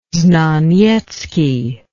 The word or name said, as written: znaniecki